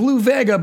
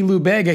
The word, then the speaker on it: Lou Bega: It's a hard B. – A hard B. – Because, like, if it was Lou Vega, but he's, you know, he's a Latin guy, usually the V is pronounced a B anyway. So it would be Lou Bega.